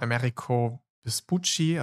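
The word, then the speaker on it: Amerigo Vespucci: Also einer der wichtigsten Entdecker oder Schriftsteller, die viel über die Entdeckung Amerikas geschrieben hatte, der den Namen Americo Vespucci, also ein Italiener hatte, danke, dass ich gut ausgesprochen habe,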